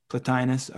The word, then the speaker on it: Plotinus: No, I mean, it just reminded me, there's a quote from, is it Plotinus or Plotinus? How would you say that? – Plotinus, okay. That's how I would say it too, but I've heard it both ways and I never know how to pronounce shit. – If it's Latin, it's Plotinus. – Well, fuck that, I don't like that. – Fine. but we would say Plotinus.